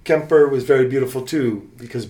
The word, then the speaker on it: Quimper: Well, no, Kemper is how they pronounce it. Okay. Yeah, because of Breton.